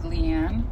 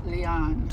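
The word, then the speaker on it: Liane: But another Leon, I heard it's pronounced, because we said Leanne Moriarty, and it's Leon.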